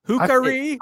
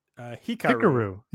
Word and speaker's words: Hikaru: Well, Dasha says it, Hikaru.